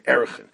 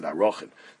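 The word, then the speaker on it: Erachin: So this is the Mishnah in Erechin, and it says, others pronounce it Arochin.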